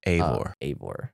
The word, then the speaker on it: Eivor: But he's also there to steal Ivor. Can't remember how they pronounce it. – Eivor.